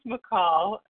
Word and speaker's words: Michal: My name is McCall, and that's spelled M-I-C-H-A-L, and my last initial is S as in Serenity.